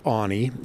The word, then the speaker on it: Aune: Yeah, my name is Keith Aune. That's spelled A-U-N-E, and I am the director of bison programs for the Wildlife Conservation Society.